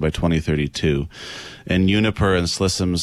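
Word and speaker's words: Ksi Lisims: Now, I read this is pronounced Slissims. Now, many say Kissilissims or Seelissims.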